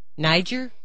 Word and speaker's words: Nyjer: Niger? – Yes, Niger. – Yeah. Niger Morgan, yeah. – How do you spell that? – N-Y-J-E-R. – Okay.